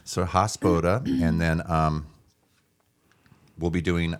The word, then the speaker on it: Hospoda: Did I say it right? – I never know. – Yeah. I know it's like, no, it's Hospoda. – Hospoda.